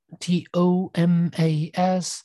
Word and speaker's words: Tomas: Thomas with no H T O M A S. Yes, because I. Uh, was born in Argentina, and the pronunciation of my name is Tomas instead of Thomas, but irrespective.